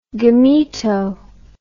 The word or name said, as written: gameto